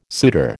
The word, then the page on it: suitor: American English